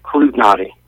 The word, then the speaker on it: Klutinoty: Yeah, it's Klutinoty.